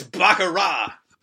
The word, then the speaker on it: baccarat: It's baccarat! – Baccarat!